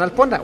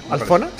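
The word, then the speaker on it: Alphona: Y, bueno, porque es co-creador también, Adrián Alpona, o Alfona, ¿cómo se pronuncia? – Alfona. – Sí. Okay, Adrián Alfona.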